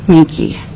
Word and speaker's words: Menke: Kathleen Menke. – Can you spell that, please, for the record? – M-E-N-K-E.